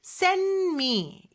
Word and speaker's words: send: send의 d는요. n 다음에 오는 d잖아요. n 다음에 오는 d나 t는 생략이 가능해요. 그리고 그렇게 발음하는 경우가 굉장히 많고요. 특히나 여기에서 send의 d는요. 자음 소리 사이에 꼈어요. 이럴 때는 더더욱이나 생략이 되거든요. 그래서 send me 이렇게 발음이 되고요.